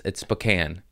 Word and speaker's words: Spokane: I was calling it Spokane because it's spelled like Spokane. But the internet, boy, did you let me know that was wrong. It's Spokane. Oh, really? Because it's not spelled that way.